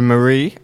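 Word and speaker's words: Marí: Pablo Mari, the acute accent is on the eye.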